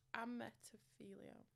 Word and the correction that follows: apotemnophilia: No, I, oh no, I'm saying it wrong. Apotemnophilia. – Apotemnophilia.